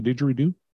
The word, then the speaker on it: didgeridoo: Do you have any facts about the didgeridoo? – Yeah, it rhymes with Zoobily Zoo.